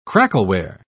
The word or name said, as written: crackleware